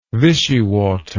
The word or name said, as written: Vichy water